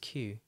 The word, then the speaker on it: queue: And we spell it super weird, like Q-U-E-U-E. It looks like queue, but it's pronounced Q.